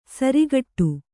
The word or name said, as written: ಸಾರಗೆಡು